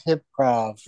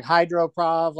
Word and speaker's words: Hyprov: Yeah, I didn't know how to pronounce it, but it's because of hypnosis, so it's Hyprov. – Yes. – Yeah. I thought it was more like hydroprov, like it was gonna be on water or something.